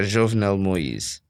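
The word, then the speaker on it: Jovenel Moïse: So President, how do you say, Moise? – Yeah, Jovenel Moise.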